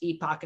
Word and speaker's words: Epoch: If you're from, I think if you're from Europe, it's pronounced Epoch, and if you're from North America, it's pronounced Epic Times.